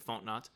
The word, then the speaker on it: Fontenot: So Terry Fontenot, I'm going to assume that's how you pronounce it, Fontenot. – You're close enough.